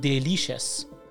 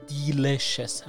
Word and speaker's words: delicious: در قسمت بعدی میخواییم بریم سراغ کلمه delicious که به معنای خوشمزه هست، اما شاید تعجب کرده باشید از تلفظش، چون خیلی ها اینو تلفظ میکنن delicious، در حالی که delicious نیست، delicious هست. یعنی در واقع دی باید اولش رو تلفظ کنید، نه ده، delicious نه delicious.